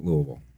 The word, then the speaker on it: Louisville: And then graduate school was Spalding University in Louisville. – Nice. And you even said it properly, too.